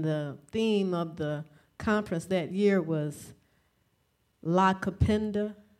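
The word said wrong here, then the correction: Nakupenda: Is that how you say it? Black love? – Na Cupenda.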